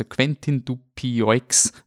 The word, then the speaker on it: Quentin Dupieux: Äh, er, er basiert auf Verwechslungskomödien und natürlich sollte man erwähnen, ähm, der Film ist von äh, Quentin, Quentin Dupieux, äh, Quentin Dupieux, wie, wie ist sie? Französische Aussprache. Also Quentin Dupieux, wenn ihr ihn wirklich googeln wollt und nicht Französisch kennt.